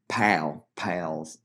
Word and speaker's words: Powell: You watched Maryville beat Alcoa 5-0, Hardin Valley beat Lenoir City 2-1, Gibbs's 7-1 victory over South Dole, and Powell, I'm pronouncing both those last ones wrong. I think it's South Dole 7-1 loss to Gibbs, and Powell's 7-0 win. I was told it's like P-A-L, just Powell.